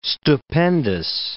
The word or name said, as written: stupendous